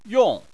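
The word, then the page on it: yong: tone 4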